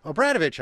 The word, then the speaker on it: Obradovich: Obradovich, I think she takes the Irish pronunciation, is a political columnist for the Des Moines Register and joins us now from the studios at Iowa Public Radio.